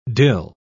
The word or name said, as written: dill